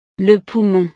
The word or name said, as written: le poumon